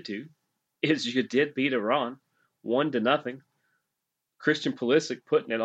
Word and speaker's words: Iran: I have been saying it wrong as well. I will work on that. Iran.